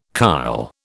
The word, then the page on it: kyle: British English